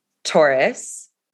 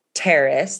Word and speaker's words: Taurus: My moon is in Taurus. – Okay. – And I'm really glad I asked her because I pronounced it Terrace the first time.